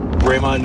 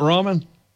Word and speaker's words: ramen: Every day, Raymond Noodles. – What is Raymond Noodles? – Raymond Noodles. Is that not ramen? He's just calling it, he's pronouncing it different.